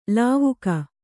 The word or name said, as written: ಲಾವಕ